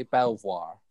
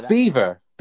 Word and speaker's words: Belvoir: If you were to pronounce it how it's spelled, you'd think it would say Belvoir. – Yeah, it's not. Belvoir. Belvoir. Belvoir. – Belvobe? It's Beaver. Beaver Castle.